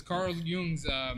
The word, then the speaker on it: Jung: It was Carl Jung's no, he's good. – Quote. How you pronounce it? – Huh? Carl Jung.